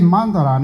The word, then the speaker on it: mantra: 因为什么念 mandala 呢？哦，M-A-N-T-R-A 嘛，啊、哦、，mandala，所以你不能念 mantra，不是 mantra，mantra 是英文的拼音，而 mandala 叫华文叫做曼达拉，华文叫曼达拉，啊、哦，那梵音叫做 mandala 或叫做。